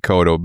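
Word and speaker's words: Ekoto: My name is Yoni Ikoto.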